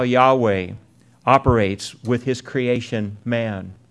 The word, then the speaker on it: Yahweh: which we pronounce Yahweh or Jehovah,